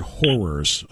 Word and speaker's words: horrors: By the way, I want to clarify when Peter said it, he said horrors of Donald Trump. Two syllables. Want to make sure that's very clear.